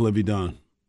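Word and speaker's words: Livvy Dunne: Is it Livy Dunn? – I think it is Livy Dunn.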